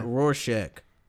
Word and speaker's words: Rorschach: Like, this was it Rorschach or how you pronounce that? – Rorschach.